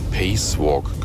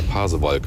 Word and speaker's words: Pasewalk: Ich hatte früher mal einen Kollegen, der immer gesagt hat, ich bin durch Pacewalk gefahren. Lange, lange habe ich überlegt, was er damit meinte, bis wir mal drauf kamen. Der Pasewalk.